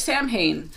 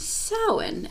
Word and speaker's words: Samhain: It looks like Samhain as English, like English speakers, American English, British English, it looks like Samhain. So it's learning, it's different. And so just, hey, I gotta tell you, come here. It's actually pronounced Samhain, and this is why.